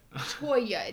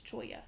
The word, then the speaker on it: Cholla: Another pronunciation we're working on is Choya and Choya. – Choya. It's Choya. – I thought we had seen both. – Yeah. But when I looked it up online, and I even went to Merriam-Webster's dictionary and listened to how it's pronounced, it is Cholla.